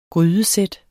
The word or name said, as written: grydesæt